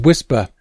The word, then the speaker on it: WSPR: An early mode still widely used today is WSPR, pronounced as whisper,